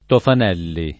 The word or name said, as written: Tofanelli